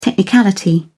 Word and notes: technicality: British English

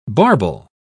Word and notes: barbel: British English

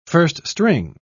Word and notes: first-string: British English

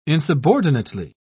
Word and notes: insubordinately: British English

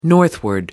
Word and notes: northward: American English

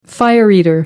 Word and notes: fire-eater: American English

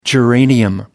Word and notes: geranium: American English

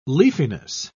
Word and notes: leafiness: British English

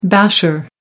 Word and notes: basher: American English

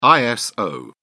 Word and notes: iso: British English

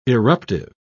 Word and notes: eruptive: British English

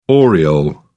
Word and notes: oriole: British English

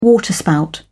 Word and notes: waterspout: British English

